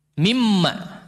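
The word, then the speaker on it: Mimma (مِمَّا): Nah, ada istilah min ma bagaimana bacanya? Mimma disambung, dia tidak diidghamkan.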